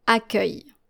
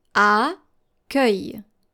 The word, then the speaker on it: accueil: And this is how you're supposed to read this word. Accueil. Accueil. Accueil. So your final L is actually silent. Accueil. Let's go to our second word, which is very close, and it is spelled A-C-C.